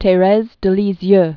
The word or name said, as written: Therese de Lisieux